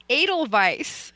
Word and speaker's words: Edelweiss: Elderweiss? – What? – Elderweiss? Your horse? – The dog? – No. – Edelweiss. – Edelweiss. Okay. I didn't know how you pronounced it. – Oh yeah, like the song, like the flower.